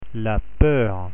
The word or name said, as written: peur